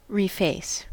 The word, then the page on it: reface: American English